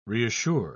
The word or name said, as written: reassure